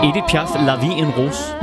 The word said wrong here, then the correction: La Vie en Rose: Som jeg overhovedet ikke udtalte rigtigt. – Nej. – Hvordan skal det siges? – La Vie en Rose. – La Vie en Rose.